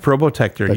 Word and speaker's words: Probotector: It was named, I'm going to pronounce this wrong, Probotector. – That is correct, Probotector. You've got it.